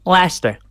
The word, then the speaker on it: Leicester: There's definitely some places up here that they cannot pronounce, but it's Leicester.